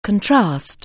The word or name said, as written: contrast